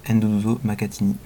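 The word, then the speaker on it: Nduduzo Makhathini: Voici qu'on approche du terme avec encore trois titres, dont Ndouzo Makatini, pardon, Ndouzo Makatini, je le prononce mieux, passé récemment au Rendez-vous de l'Erdre à Nantes.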